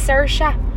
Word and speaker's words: Saoirse: You pronounce her name with an Irish accent. Saoirse. Oh. Saoirse Ronan. – Ronan. All right. – And it's pronounced Cerise, and it's funny because she's like, everyone pronounces my name Cerise. It's Saoirse. But yeah.